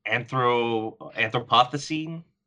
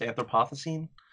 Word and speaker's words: Anthropocene: Yeah, he's like, enough with your wildfires, because he called it apparently there's some sort of anthropocene, like that's how it's pronounced, anthropocene.